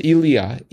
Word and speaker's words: Elia: I always thought it was Ilya Kazan. – It is Ilya, E-L-I-A.